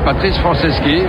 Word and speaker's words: Franceschi: Comment on prononce votre nom? – Patrice Franceschi, voilà.